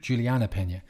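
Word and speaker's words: Juliana Peña: So a prime example of forced trash talk was Julian, how do you pronounce her name? Pena? – Juliana Pena.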